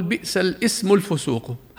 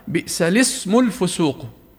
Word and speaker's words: الاسم: فيقطع همزة وصلها فيقول بئس الاسم الفسوق، هذا خطأ. والصح كما أسلفنا بئس الاسم الفسوق، فهمزة كلمة اسم هي همزة وصل دائما